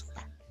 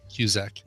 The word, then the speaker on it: Cusack: Like a Z instead of an S. Cusack. Cusack.